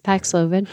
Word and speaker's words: Paxlovid: And I was in tears this weekend because the commercial for the drug Paxlovid, did I say it right? – Yes, Paxlovid.